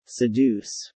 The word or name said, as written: seduce